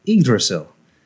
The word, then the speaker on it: Yggdrasil: And that tree is pronounced Yggdrasil.